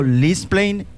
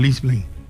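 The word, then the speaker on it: Leidseplein: Lisplein, en Ámsterdam. – Sí, señor, Lisplein.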